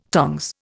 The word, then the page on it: tongs: Indian